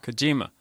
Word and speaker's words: Kojima: Next is Hideo Kojima. Did I say that right? – Kojima. – You added an R.